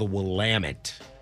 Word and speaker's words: Willamette: It is how it's pronounced, the Willamette.